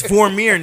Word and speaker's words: Vormir: It's Vormir, nigga.